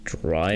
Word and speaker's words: Draino: Ich, wür ich würde gerne Drino reinmachen von City Morg und Silakami. Gesundheit. Dann ist da noch Denzel Curry drauf und Sos Mula. Was zum Ersch? – Drino. Ich habe keine Ahnung. – Drino. Okay, wie, wie schreibt man das? D-R-A-I-N-O.